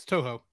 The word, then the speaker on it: Toho: Did I say it right? Toho? – Yeah, it's Toho.